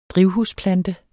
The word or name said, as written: drivhusplante